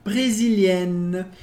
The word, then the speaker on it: brésiliennes: Agora, no plural, nous sommes, nós somos, nous sommes brésiliennes. A pronúncia não muda de brésilienne, a gente não pronuncia o S do final, mas brésilienne.